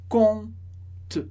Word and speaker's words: compte: O último comentário importante sobre compte, aparece o P na palavra, na hora de escrever, que a gente não pronuncia. A gente diz compte, compte.